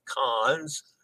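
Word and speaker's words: Cannes: He gave you pronunciation information for several ports, including cons, which is pronounced oh the S is silent. Con. That's right. I knew that.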